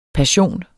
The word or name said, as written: passion